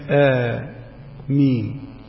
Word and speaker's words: آمين: أما كلمة آمين فنطقها همز حرف همزتين بدل أمين، ألف على همزة وألف على همزة أمين، فسهلت فسميت آمين، فهو مد بدل مقداره حركتان فقط.